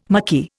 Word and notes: mucky: Indian

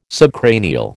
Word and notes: subcranial: American English

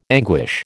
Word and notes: anguish: American English

